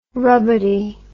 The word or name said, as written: rubbity